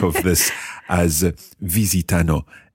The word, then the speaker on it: visitano: Visitiamo, visitate, visitano. Okay, Ayla, well done. But just watch your accent on the third person plural. Visitano.